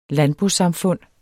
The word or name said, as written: landbosamfund